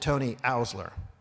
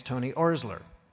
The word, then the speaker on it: Tony Oursler: Now, I've always pronounced it Tony Oursler. My staff has been correcting me now for about the last 6 months, saying it's Tony Oursler. Finally today, someone told me, no, it is Tony Oursler.